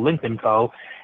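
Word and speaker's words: Lynk & Co: They've also launched a new brand called Link & Co., which, if you pronounce it quickly, sounds like Lincoln, but it's called Link & Co.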